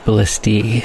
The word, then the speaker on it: ballistae: The plural is pronounced ballistae.